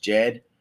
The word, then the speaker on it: Djed: Once again, it's spelled out DJED, but I'm pretty sure it's pronounced Jed. At least that's the way Charles has been saying it.